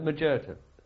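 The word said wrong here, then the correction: Macerata: Oh no, not in. They pronounce it Majerata. – Sorry, I'll keep it Majerata.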